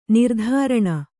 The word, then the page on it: ನಿರಾದರಣೆ: nirādaraṇe